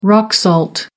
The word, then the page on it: rock salt: American English